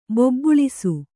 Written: ಬೆಬ್ಬಳಿಸು

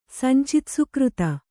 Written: ಸಂಚಿತಸುಕೃತ

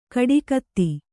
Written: ಕೊಡಗಿತಿ

